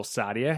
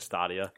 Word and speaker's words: Stadia: The announcement and presentation of Google Stadia has caused two of Slightly Mad's investors to walk away from the project.